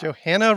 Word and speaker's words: Johanna: Johanna rhymes with Montana.